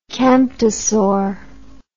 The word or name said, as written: camptosaur